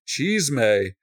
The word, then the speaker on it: chisme: C-H-I-S-M-E. – Oh, okay. Chisme. – Here's the beautiful thing about Spanish. It's spelled just like it sounds. Like every time.